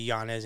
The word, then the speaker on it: Yanez: and it's an Inye, not an In. And so he said he doesn't want to be Yanez anymore. He wants the correct pronunciation, which I totally am behind. Mm-hmm. I totally respect that. So we're going to respect it and change it as well with the Yanez.